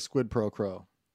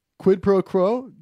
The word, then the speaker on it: quid pro quo: It sounds like squid pro crow.